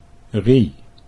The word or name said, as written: Rie